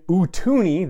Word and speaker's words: Utooni: And then Utooni, that's how it's, you know, pronounced, spelled U-T-O-O-N-I, which is basically two Jawas, one standing on the shoulders of another.